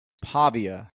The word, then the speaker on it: Pavia: So without further ado, we have Vince Pavia. Am I saying that right? – Yeah, you're saying it right. Pavia, Pavia. It depends on what part of the country or world you're in.